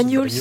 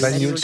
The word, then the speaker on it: Banyuls: Comment on le prononce? – Banyuls. – Banyuls. – Oui, ouais. C'est ça.